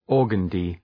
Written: organdie

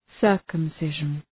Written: circumcision

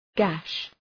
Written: gash

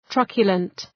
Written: truculent

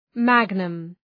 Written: magnum

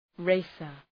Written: racer

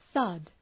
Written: thud

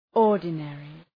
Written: ordinary

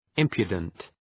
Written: impudent

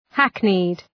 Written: hackneyed

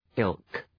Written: ilk

